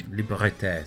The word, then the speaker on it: Liberté: That is Belgium for Jacques Houston with Fleur de Libre. – Very nice indeed. – Liberté, sorry. Liberté. Is that the correct pronunciation? – Fleur de Liberté. Liberté.